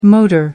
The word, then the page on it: motor: American English